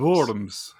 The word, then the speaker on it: Worms: Is that how you say it? It's spelt Worms. – Worms. – Worms? Oh, I should have known the V.